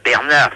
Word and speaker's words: Bernard: Ben, Bernard, si, c'est mon prénom, mais ça s'écrit pas comme vous me le prononcez. Ça s'écrit B-E-R-N-A-R-D.